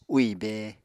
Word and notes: úì bée: Apatani

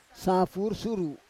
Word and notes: safur suru: Roon

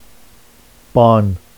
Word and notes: bɑn: Vafsi

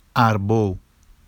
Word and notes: arbow: Vafsi

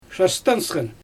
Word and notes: ʃastɛnpsxɛn: Xyzyl

